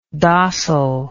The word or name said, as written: dossil